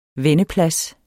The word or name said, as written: vendeplads